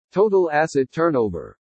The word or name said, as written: TOTAL ASSET TURNOVER